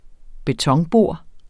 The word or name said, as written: betonbor